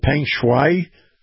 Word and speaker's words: Peng Shuai: is it Peng Shuai, disappearance, the tennis player? – Close enough. – Okay.